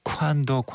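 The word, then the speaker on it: Quando: 아주 뭐 캐나다 사람, 그 영어권 사람이라 원래는 꽌도에 가까운데 쿠안도 큐 발음 확실하게 해주시는 우리 두 분 마이클 부블레, 넬리 펄타도의 쿠안도 쿠안도 쿠안도였습니다.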